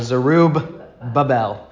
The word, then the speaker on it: Zerubbabel: Yeah, you pronounce both B's, so Zerubbabel.